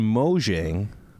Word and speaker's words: Mojang: First item on Tip of the Tops, Microsoft and Mojang.